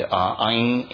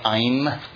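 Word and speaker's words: Aing (ऐं): And Aing is Saraswati's bija. Is there any more of those? There you go. Aing is Saraswati's bija. So you don't say Aim.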